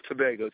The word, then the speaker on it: Tobago: Did I pronounce that right? – Tobago. Trinidad and Tobago. – Okay. – Yes, correct.